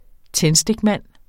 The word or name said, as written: tændstikmand